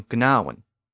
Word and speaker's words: gnaw: Of course, we still spell it with that G at the beginning, G-N-A-W, because the initial G was once pronounced.